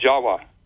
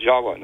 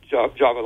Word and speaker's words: Jawa: Furthermore, appending the suffix of T-I-S, the word Atlantis, it also is spelled T-I-V or pronounced T-I-W. The V's are pronounced as W's in Dravida, such as in Java. The word in Java, the island Java, you know, is pronounced Jawa. Actually, Indonesians will pronounce it Jawa, not Java like we do.